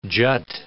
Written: jut

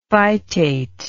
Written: phytate

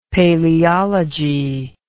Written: paleology

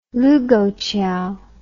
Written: lukouchiao

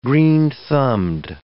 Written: green-thumbed

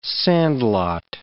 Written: sandlot

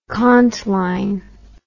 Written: contline

